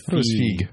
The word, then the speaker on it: Feige: Is it Feige or Feige? – Feige. – Feige, I think. – Yeah, it rhymes with Teague. – Yeah. If it were Feige, well, I would assume This is definitely the Avi Arad administration when Avi Arad was mainly in charge of this.